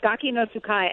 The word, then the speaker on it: Gaki no Tsukai: Like, I know there's a show called Gaki no Tsutai? I don't even know how to say it. – Yeah, that was right. You were close. Gaki no Tsukai, and I do.